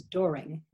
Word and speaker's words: Doering: So first of all, my last name is Doering, D-O-E-R-I-N-G. You can pronounce it Doering. It's German.